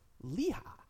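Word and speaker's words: Lichaj: How do you pronounce his last name? – Leha.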